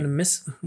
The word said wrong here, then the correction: anonymity: Anonymity, anonymity.